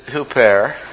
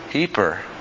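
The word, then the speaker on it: huper (ὑπέρ): By the way, whenever we pronounce biblical names and places and words, I always anglicize them. This is huper. However, if you would listen to modern Greek, you wouldn't hear them saying huper. You want to know what they would say? Heper. You say, what? Heper? Well, that's just the modern way of pronouncing that word.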